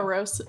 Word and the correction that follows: Amoruso: Amoruso. Amoruso.